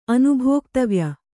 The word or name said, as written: ಅನುಭೋಕ್ತವ್ಯ